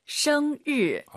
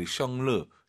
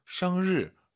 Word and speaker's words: 生日: 정말 많은 분들이 生日, 生日, 르. 한글의 르 있잖아요. 그게 들리면 안 되세요. – 没错. – 生 하시고 입 벌린 상태에서 生日. 혀만 천장에 대면서 드럭 生日.